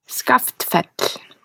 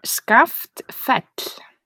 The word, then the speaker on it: Skaftfell: Skaftfell. – I'm sorry. – Yes, Skaftfell. – I would never have gotten that by reading. I would have said Skaftfell.